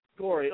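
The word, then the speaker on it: Koire: It's Rosa Kior or something. I can't. It's K-O-I-R-E. – Corey.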